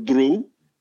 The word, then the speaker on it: Dru: Mira, ya me conocen por Drew, y así se escribe D-R-U, sí, bien sencillo.